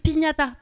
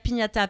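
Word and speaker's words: Pinata: Moi, si vous voulez me suivre sur les réseaux, je m'appelle Ruby Pignata parce que j'aime bien les bonbons et les battes de baseball. Voilà, ça c'est dit. Ruby, ça s'écrit R-U-B-Y, Pignata, P-I-N-A-T-A, R-U-B-Y, P-I-N-A-T-A.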